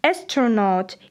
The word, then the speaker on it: astronaut: Запомните, правильно произносить «astronaut» и «космонавт».